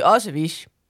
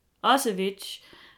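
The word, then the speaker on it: Ocewitsj: Ja, fordi jeg får jo meget lyst til, igen, fra Horsens, der siger vi Otsevich. – Otsevich.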